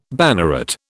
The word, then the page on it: banneret: British English